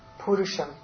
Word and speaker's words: Purusha: And sometimes Purusha has a long U, sometimes a short U, same meaning. – Yeah, I've never seen a long U. – No, even in the Panchadashi, that Purusham has a long, so it does come with long U or a short U. Normally it's short U.